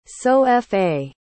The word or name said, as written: sol-fa